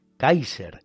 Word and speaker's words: Kaiser: es lo que significa Kaiser, Kaiser, pronunciación alemana de la palabra César,